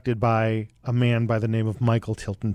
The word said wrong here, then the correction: Tilson: Thomas. Did I say that right? Michael Tilson Thomas.